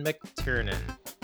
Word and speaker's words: McTiernan: This week's movie, Die Hard, released near 1988, directed by John McTiernan. Did I pronounce that correctly? – Yes. – Yeah, that sounds right.